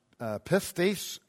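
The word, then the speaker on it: pistis (πίστις): This particular noun is pronounced pistis, and it is used 244 times.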